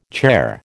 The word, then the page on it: chair: American English